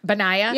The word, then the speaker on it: Benaiah: I never say his name right. – Benaiah? Yeah.